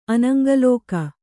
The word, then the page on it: ಅನಂಗಲೋಕ: anaŋgalōka